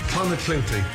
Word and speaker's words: Clonakilty: Clonakilty, thank you.